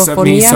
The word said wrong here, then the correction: misofonía: miso- misofon- misofonía.